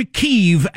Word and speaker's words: Kyiv: But I heard a Ukrainian explain that Russia pronounces it Kiev, but Ukrainians have always called it Kiev. So it's a signal of solidarity to say it the way Ukrainians say it. So fanfare, Michael. I am officially changing to Kyiv as of right now. From now on, I'm pronouncing it Kyiv because that's the way the Ukrainians pronounce it. Kiev is the Russian pronunciation.